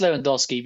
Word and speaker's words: Lewandowski: It's Lewandowski. I know it's Lewandowski because I also used to speak to someone who was Polish and also into football, and he told me it's Lewandowski. – It is Lewandowski.